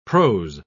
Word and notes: pros: British English

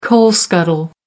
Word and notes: coal scuttle: American English

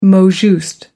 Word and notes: mot juste: American English

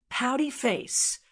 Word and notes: pouty face: American English

